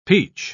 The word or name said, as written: peach